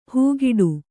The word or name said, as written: ಹಾಗಡ